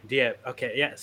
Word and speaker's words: Diab: Oscar Isaac and director Mohamed Diab. I don't know if I'm saying that right. – Diab.